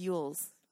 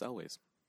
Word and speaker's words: Elwes: Carrie Elwes, is that how you say it? – That's how I've always heard. – I always thought it was Yules, Carrie Yules. – I've always heard it pronounced Elwes.